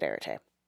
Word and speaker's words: Arete: For magic rules, you will roll your Arete stat, and if you're someone who pronounces Arete differently, just come fight me in the comments, we'll battle it out. Arete. I'm going to pronounce it Arete.